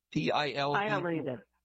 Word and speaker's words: pileated: Pileated woodpecker? Is that the way you pronounce that word? – Pileated. – Pileated. Sorry about that.